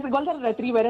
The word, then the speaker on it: Golden Retriever: Es Golden Retriever, es como el perro. – Ah. – Se refiere precisamente como a hombres que destilan o proyectan como una especie de energía como de perro Golden Retriever.